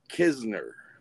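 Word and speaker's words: Knizner: Nizer? – Andrew Kisner, I want to say is how you say it. – Okay. – I don't know why it's said that way, but I believe that's right.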